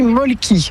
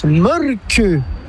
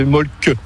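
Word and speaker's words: Mölkky: Inventé en Finlande en 1996, ce jeu de quilles s'écrit M-O-T-R-E-M-A-L-K-K-Y. – Ça s'écrit Molki, mais ça se prononce Molke. – Le Molki. – Plutôt un E qu'un E, mais pas un U. – Donc ça fait Molke.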